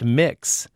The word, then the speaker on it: Mx: And one seems a clear sign of the times, the use of of MX, pronounced mix, as a form of address.